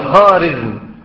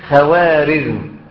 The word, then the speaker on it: خوارزم: هناك تجار من المغول أتوا إلى مملكة خوارزمشاه، والنطق الصحيح خوارزمشاه، لأن هذه الواو تسمى بالواو المعدولة، وهذه الواو المعدولة لا تنطق، ولكن الذين لا يعرفون اللغة الفارسية ينطقونها، فهي خارزم، الدولة الخارزمية، وجلال الدين الخارزمي، وخارزمشاه، لكن الناس يقرونها إيه؟ خوارزم، هي خارزم.